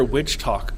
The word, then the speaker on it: WitchTok: You mentioned earlier witch talk. What is that? – It's a hashtag. It's it's spelled exactly as you would think. WITCHTOK. And oh, witch talk. Okay. – Yeah, witch talk.